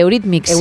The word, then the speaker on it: Eurythmics: es la de Sweet Dreams de Mix. De Eurythmics, sí, sí, sí. casi lo he dicho bien.